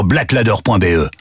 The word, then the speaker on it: Blaklader: Va vite voir sur blackladder.be. Black Ladder, ça s'écrit BLAKLADER.